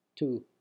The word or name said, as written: tou